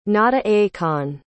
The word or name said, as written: nata-akon